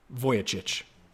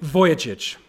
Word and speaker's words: Vujicic: Well, I would like to share with you an amazing, amazing brother of Christ, Nick Vujicic. And that is how you pronounce that. I wrote down the pronunciation. Nick Vujicic, incredible brother of Christ.